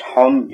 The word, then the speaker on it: chun: Now that word, Charlotte, C-H-U-N, but it's pronounced hun. You don't hear the C.